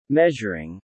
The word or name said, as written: measuring